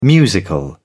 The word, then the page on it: musical: British English